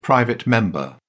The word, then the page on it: private member: British English